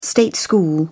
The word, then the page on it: state school: British English